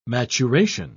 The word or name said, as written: maturation